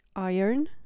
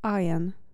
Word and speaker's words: iron: W wymowie amerykańskiej iron, w wymowie brytyjskiej iron. Iron w amerykańskiej, iron w brytyjskiej.